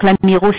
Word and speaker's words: Amirault: Donc, l'Amiraux s'écrit L-A-M-I-R-A-U-L-T.